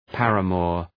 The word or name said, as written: paramour